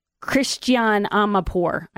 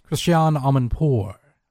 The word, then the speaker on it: Christiane Amanpour: She was on CNN with Christiane Amanpour. I might be butchering that name. I always have trouble with that. – Close. Christiane Amanpour.